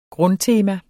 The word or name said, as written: grundtema